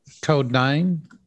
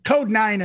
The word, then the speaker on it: Kode9: So if you're a fan of like dubstep, and when I say dubstep, I don't mean necessarily dubstep like uh, what's the Coda and the Space Ape. Uh, oh, Am I saying that right? – Kode9. – Code nine in the Space Ape.